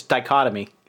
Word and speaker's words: dichotomy: I misused dictomy, huh? Or did I just mispronounce it? – Well, it's dichotomy.